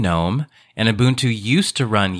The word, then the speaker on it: GNOME: The- with a G, and I'm going to pronounce it the Croatian way, which is GNOME.